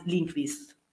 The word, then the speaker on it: Lindqvist: Lasciami entrare, mi sono cercata la pronuncia IPA, Jon Arvid Lindqvist, perché è svedese,